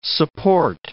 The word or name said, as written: support